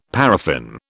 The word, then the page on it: paraffin: British English